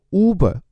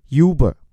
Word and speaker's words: Uber: 比如说打车软件，虽然在中国现在已经被滴滴击垮，叫做优步，它的英文本名应该念作 Uber，而不是很多人念的 Uber。